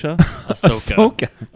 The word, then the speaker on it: Ahsoka: Ahsoka? – Ahsoka.